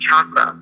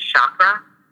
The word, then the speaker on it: chakra: It actually is pronounced chakra clearing. – Cha, like chair. – For all parts, yeah, with like CH, with like a hard CH. But the common way of saying it is chakra.